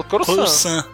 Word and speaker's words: Coruscant: Vocês pronunciam Coruscant ou Coruscant? – Falo Coruscant. Coruscant.